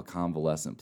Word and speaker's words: convalescent: convalescent plasma. Is that correct? – You're right. – Yep. Awesome. – Convalescent plasma. – I really studied hard on that one. So can you talk about how convalescent plasma is helping fight the COVID-19 virus? – Yeah, absolutely.